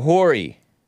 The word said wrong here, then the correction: Horry: Oh, Ori. I call it Hori. H-O-R-R-Y. Pronounced O-R-E.